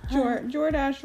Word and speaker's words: Jordache: Yeah, it's like no, it's like Jordache rhymes with DoorDash.